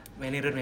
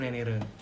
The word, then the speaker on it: Menirun: Menirun, Menirun. Menirun. – Bacanya itu Menirun kan? – Iya BC Menirun.